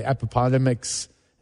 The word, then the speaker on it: Appomattox: 150 years for the surrender at the Appomattox, that's how you say it, courthouse.